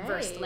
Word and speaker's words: Versed: Versed, like V E R S E D, like Versed. Oh, conversed. Like well versed.